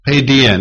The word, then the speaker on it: paideia: Paideia, and it's spelled P-A-I-D-E-I-A, paideia.